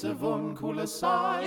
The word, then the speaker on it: avunculicide: It's Avunculicide.